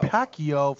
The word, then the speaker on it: Pacquiao: Pacquiao fight Manny Pacquiao.